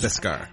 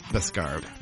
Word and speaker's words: Beskar: Beskar. – I had a like three or four syllable. – No, it's Beskar.